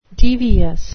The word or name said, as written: devious